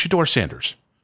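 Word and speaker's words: Shedeur Sanders: But there are other people who cannot say the damn name right. It's Shador Sanders. Let's just Shador Sanders.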